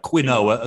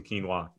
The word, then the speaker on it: quinoa: So his main course apparently is it quinoa? Quinoa. That's how you pronounce it, isn't it? Yeah. Not quinoa, as I used to call it.